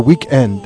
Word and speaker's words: weekend: They're like, you know, you're listening to Weekend. I love the reversed kind of emphasis on the wrong syllable. Weekend.